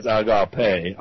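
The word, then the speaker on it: agape (ἀγάπη): I do believe that's agape. Agape.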